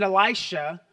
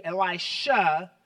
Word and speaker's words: Elisha: By the way, any scholars in the room, I know it's pronounced really Elisha, but I grew up with Elisha, and old habits die hard.